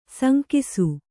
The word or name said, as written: ಸಂಕಾಶ